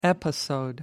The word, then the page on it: episode: American English